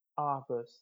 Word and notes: Arbus: English

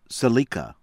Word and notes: Seleka: from C.A.R.

